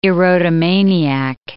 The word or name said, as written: erotomaniac